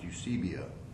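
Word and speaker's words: Eusebia (εὐσέβεια): But it's from a Greek word, Eusebia, and it's pronounced Eusebia.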